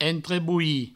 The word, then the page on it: entrebolhir: entreboui; provençal